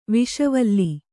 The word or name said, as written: ವಿಷವಲ್ಲಿ